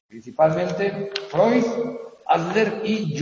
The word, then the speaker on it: Freud: Se escribe Freud, pero se pronuncia Freud, el austriaco, ¿verdad?